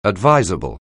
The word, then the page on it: advisable: anglais